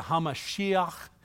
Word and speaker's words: HaMashiach: and to restore and rebuild Jerusalem, the city, not the temple, until the anointed one, that's Hebrew for the Messiah, HaMashiach, as it's pronounced, we call him the Messiah.